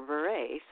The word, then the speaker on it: Bure: And it's called Switched for Christmas, premieres Sunday, November 26th, 8 Eastern, 7 Central, stars Candace Cameron Bure. Is that how you say her name? – Bure. – Okay. I always get stuck on that because I forget if it's Burr or Bure.